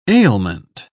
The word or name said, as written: ailment